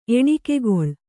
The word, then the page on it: ಎಣಿಕೆಗಳೆ: eṇikegaḷe